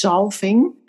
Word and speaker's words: zalving: It's in Dutch, it's zalving. And it's like the anointment. – It's zalving? – Zalving. – Oh, how do you spell it? Z? – A? L? V? Like zalving. I think it's like zalving, only with a Z and a V.